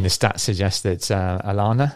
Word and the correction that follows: Oliana: have I said that right? – Oliana. – Oliana.